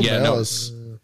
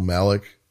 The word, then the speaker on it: Malice: I'm sure Michael Malice. – It's funny that they brought him up, right? And they said his name wrong, Michael Malik.